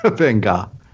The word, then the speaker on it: Van Gogh: my Van Gogh on. – Van Gogh, pronounced perfectly.